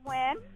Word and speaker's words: Nguyen: It's Nguyen. – Nguyen. – No, she said win. The silent N.